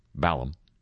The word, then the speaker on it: Balham: The meta joke in all of this is that the name of the community being profiled in this pseudo documentary is Balham, as it's pronounced in Britain, Balham, and it's like Downey, I guess, more than any place else.